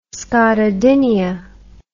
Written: scotodinia

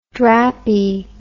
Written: drappie

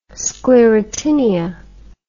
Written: sclerotinia

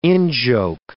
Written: in-joke

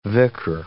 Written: vicar

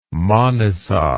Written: manisa

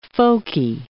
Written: folky

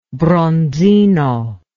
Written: bronzino

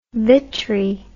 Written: vitri

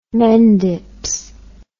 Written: mendips

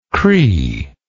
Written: cree

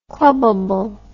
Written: clubability

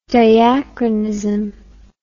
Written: diachronous

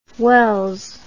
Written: wels

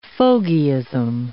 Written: fogyism

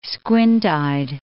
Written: squint-eyed

